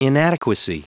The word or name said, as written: inadequacy